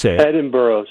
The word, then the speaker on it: Edinburgh: Edinburgh. Say it with me. Edinburgh. – Edinburgh. Boy, it sure isn't spelled like that.